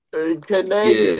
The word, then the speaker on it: Kniga: Like we don't, The K is silent. Kniga.